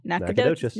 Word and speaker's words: Nacogdoches: Nacogdoches. – Nacogdoches. – It's pretty good. – Do we have a pronunciation? – Nacogdoches. – Nacogdoches. Nacogdoches, baby.